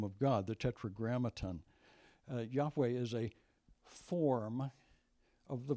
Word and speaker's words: Yahweh: Lord is the name Yahweh. That's probably the correct pronunciation. Most modern scholars say very likely there's a stop at the H and you start a second syllable.